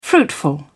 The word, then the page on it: fruitful: British English